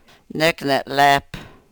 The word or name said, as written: nek ne-lep